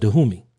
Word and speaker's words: Dahomey: The Kingdom of Dahomey, actually pronounced Dahomey.